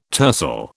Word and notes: tercel: British English

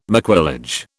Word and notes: maquillage: British English

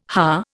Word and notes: haar: Indian

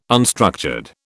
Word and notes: unstructured: British English

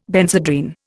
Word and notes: Benzedrine: Indian

